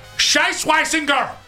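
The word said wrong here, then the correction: Schwesinger: Well, I think it's Schweisinger. That's how you say his name.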